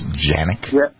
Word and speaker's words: Canik: But then again, if you look at the Turkish, isn't Canik Turkish? Or isn't it actually pronounced Janik? – Yeah, I believe so.